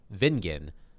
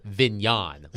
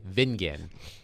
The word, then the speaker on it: Vingen: And I would appear on the station, and one of the hosts thought that my last name, which is pronounced Vingen, was a little bit fancier, and he pronounced it Vignon, like rhymes like rhymes with mignon. And I had to tell him his name was Danny. I said, Danny, I appreciate you sprucing up my last name and saying it's Vignon, but it's actually Vingen.